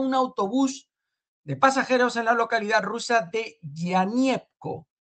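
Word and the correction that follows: Yenákievo: Yenakievo, madre mía, es que resulta bastante complicado la pronunciación.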